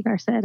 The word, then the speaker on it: Garced: I think that's actually the first time I've heard someone say my name besides when I graduated, Dr. Stacy Garced.